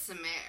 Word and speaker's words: Samaire: I don't think that's how you pronounce that name. I'm just going to throw that out. – How do you think you pronounce it? Samer? – I think it's Samer. – Samer? – Yeah. – S-A-M-A-I-R-E. Samer? Yeah. All right. Samer Armstrong.